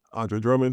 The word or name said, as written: Andre Drummond